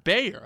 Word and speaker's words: Bayer: Because the CEO, I believe, pronounced it Bayer. I was just listening to an interview of his, which is why I went with Bayer. Also, it's spelled B-A-Y-E-R.